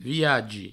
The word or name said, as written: viagi